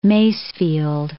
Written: Masefield

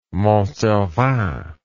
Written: Mont Cervin

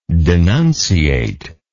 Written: denunciator

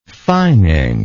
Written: fining

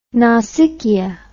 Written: nausicaa

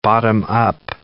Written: bottom-up

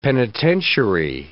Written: penitentiary

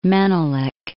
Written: Menelik II

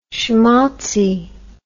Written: shmaltzy

